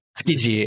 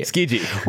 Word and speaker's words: Tsukiji: Let's just leave it at Tsukiji. – Tsukiji.